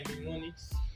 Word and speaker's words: mnemonics: So, they are referenced by mnemonics. How do they pronounce mnemonics? – Mnemonics. – Yeah. – The M is silent, right? – Mnemonics.